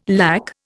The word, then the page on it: lakh: Indian